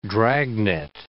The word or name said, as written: dragnet